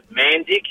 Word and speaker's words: Mandic: My surname and hers is Mandic. It's spelled M-A-N-D-I-C.